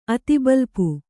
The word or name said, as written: ಅತಿಬಲ್ಪು